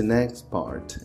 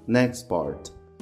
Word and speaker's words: next part: E perceba que esse next part, eu não pronuncio T quando eu falo ele corrido, ó, o next no final. Eu não falo next part, tá? Eu junto esse T com o P do part, next part.